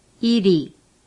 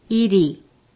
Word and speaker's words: 이리: For example, 이리. – Repeat. – 이리, 이리.